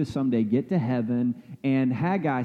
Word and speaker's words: Haggai: Some people pronounce it Haggai, but there is no I before the A, so it's just Haggai, okay?